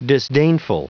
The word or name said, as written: disdainful